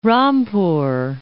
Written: Rampur

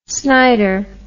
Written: snider

